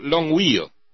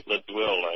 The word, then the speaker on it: Longueuil: And in up in your neck of the woods, well, not terribly far away, maybe a little community called Longueuil, or God knows how it's pronounced, Longueuil, in Quebec. Okay. – Longueuil, I would say.